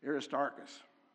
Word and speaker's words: Aristarchus: Aristarchus.